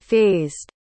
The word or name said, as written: fazed